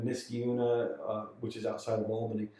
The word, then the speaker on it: Albany: Well, if you look at the how it's spelled, it is one L. So it's Albany.